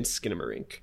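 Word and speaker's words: Skinamarink: Real quick too, I pronounced it incorrectly. I was saying Skidamarink. It's skin like your human skin. Skidamarink is how it's pronounced.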